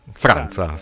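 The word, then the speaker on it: França: Xenia Franza, o Franca, che è brasiliana. – Franza. – Sì, perché c'è la C con la Gigetto sotto.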